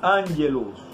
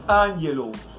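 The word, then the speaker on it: ángelos (ἄγγελος): Y en el griego se pronuncia ángelos Que pone la boca como así. ángelos Pero en ambos lo que significa es mensajero.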